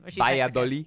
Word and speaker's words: Valladolid: Valladolid.